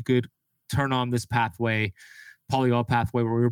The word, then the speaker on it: polyol: Is it called the polyol pathway? Yeah. Okay, the polyol pathway.